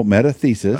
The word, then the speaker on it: metathesis: Megathesis? – No, metathesis. Ah.